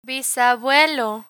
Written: bisabuelo

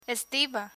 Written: estiva

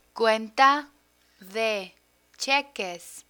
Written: cuenta de cheques

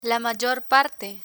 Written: la mayor parte

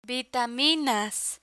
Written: vitaminas

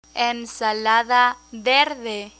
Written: ensalada verde

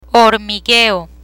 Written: hormigueo

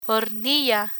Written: hornilla

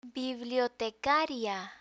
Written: bibliotecaria

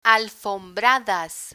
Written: alfombradas

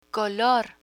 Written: color

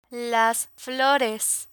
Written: flores

